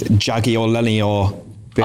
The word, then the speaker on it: Jagiellonia Bialystok: I just wonder what kind of chance Rangers would have come up with to rhyme with Jagiellonia. – Bialystok. I beg your pardon. Enough of your language. – Was that not the right pronunciation? – Jagiellonia Bialystok. Yes.